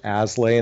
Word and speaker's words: Aslay: But if you Google my name, Jonathan Aslay, and it's spelled Aslay, you'll find a ton of content out there.